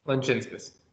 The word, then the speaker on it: Lanchinskas: I'm not pronouncing that correctly, I'm sure. So if you want to correct me there, feel free. – Lanchinskas. – Oh, man, I wasn't far off.